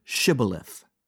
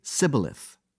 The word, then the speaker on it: Shibboleth: Knowing they had difficulty with the SH sound, he chose the word Shibboleth, realizing that they would say Sibboleth, and by that defect indicate that they were enemies.